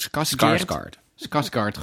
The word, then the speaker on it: Skarsgård: Skes, zeg ik dat goed? – Skarsgård is gewoon, oké.